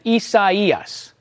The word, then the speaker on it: Isaias: Isaias. Kind of like four-ish syllables. You could make the argument that it's three.